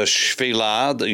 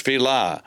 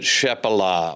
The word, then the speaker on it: Shephelah: And then as you rise up over the Shvilah, you're going to read about that word. It's even transliterated from Hebrew into English, Shvilah. You will probably pronounce it Shephelah or Shephelah or something like that because that's the English spelling of it.